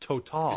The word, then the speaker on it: Total: I prefer Total, or I think maybe internationally it's pronounced Total.